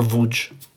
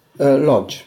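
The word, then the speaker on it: Łódź: From Lodz. – From what? – Or Wódz. – Yes. – How do you say it in German? – Lodz.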